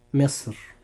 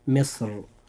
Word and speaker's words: مصر: كلمة مصر يجوز ترقيقها وتفخيمها لأن حرف الراء فيها سكن بعد كسر للوقف وفصل بينه وبين الكسر بحرف استعلاء، وذلك عند الوقف عليها. – فننطق هذه الكلمة هكذا، مصر. هذا في حال الترقيق، أما في حال التفخيم فتنطق مصر.